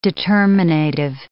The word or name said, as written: determinative